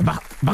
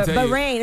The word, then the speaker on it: Bahrain: Did I say that right, Bahrain? – Bahrain. – Bahrain. – No, but it's, yeah, Bahrain. It's Bahrain.